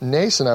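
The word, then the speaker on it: Mnason: And in Jerusalem, they stay at the house of Nason, I believe the M is silent there, Nason of Cyprus.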